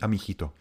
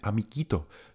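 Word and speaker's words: amiguito: Ale to není žádný amichito. My musíme mít amikito. To znamená, že já tam nechám účko uprostřed mezi g a i. Účko je neznělé, ale umožní mi vyslovit tu kombinaci g u i jako g.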